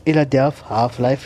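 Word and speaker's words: Illadelph Halflife: Yeah, Illadelph Half Life, September 1996. What was I saying, right? – You continue. – Did I say it right? – Yeah, it's okay.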